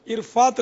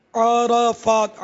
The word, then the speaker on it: عرفات: دوسرا انہوں نے مسئلہ پوچھا عرفات سے مزدلفہ, تو اس کا وہ تلفظ عرفات نہیں ہے, عرفات عین اور را پر دونوں پر زبر ہے, عرفات اور مزدلفہ نہیں ہے, مزدلفہ لام کے نیچے زیر ہے.